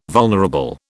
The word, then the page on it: vulnerable: British English